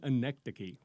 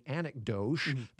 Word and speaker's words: anecdoche: See, now, I learned just now how to pronounce the word, because when I looked at it, I thought immediately anecdoche, because it looks like anecdote.